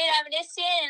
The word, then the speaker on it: Avedisian: Hey everybody, it's Jade Avedisian, and I'm excited to do my first podcast tonight.